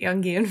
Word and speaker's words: Jungian: If you've ever heard of like Jungian or yeah, that's how you say it, Jungian philosophy or psychology.